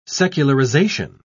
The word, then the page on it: secularization: British English